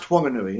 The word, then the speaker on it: Whanganui: He ruled an area from Christchurch all the way up to Whanganui, with an H. That's with an H, yeah. – Yeah, pronounced Whanganui, yeah.